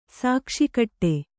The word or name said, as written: ಶಾಕಶಾಕಟ